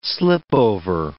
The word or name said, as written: slipover